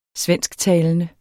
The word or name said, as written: svensktalende